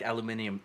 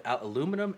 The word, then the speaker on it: aluminium: In fact, aluminium is correct. The difference is both aluminum and aluminium are both accepted as correct spellings and pronunciations.